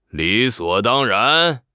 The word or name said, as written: 理所当然